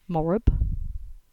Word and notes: Morrab: English

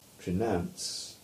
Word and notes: Trenance: English

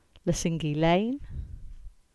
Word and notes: Lesingey Lane: English

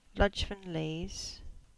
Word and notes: Ludgvan Leaze: English

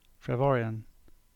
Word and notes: Trevorian: English

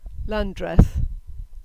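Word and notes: Lanndreth: Cornish

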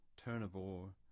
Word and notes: Turnavore: English

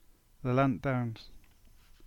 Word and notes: Lelant Downs: English

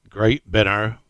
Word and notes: Great Binner: English

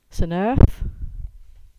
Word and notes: Saint Erth: English

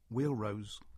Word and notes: Wheal Rose: English

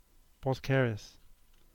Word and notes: Boskerris: English

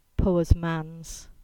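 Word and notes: Cripple's Ease: Cornish

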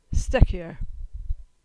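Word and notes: Stekyer: Cornish

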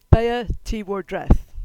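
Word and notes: Baya Ti war Dreth: Cornish

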